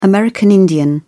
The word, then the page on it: american indian: British English